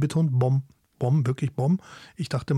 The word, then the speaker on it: bom: Das M in bom wird eher nasal ausgesprochen, so ein bisschen wie ein sanftes Summen am Ende.